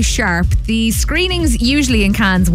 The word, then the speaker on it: Cannes: Now, you might have spotted the headlines that the film festival is going on in Cannes, or Cannes, I suppose, as the French would pronounce it, right across this week.